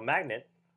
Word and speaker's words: magnate: So basically, there was this industrial magnate, or magnate, is that how you say it? – Magnate. – Magnate, that's dumb.